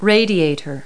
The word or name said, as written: radiator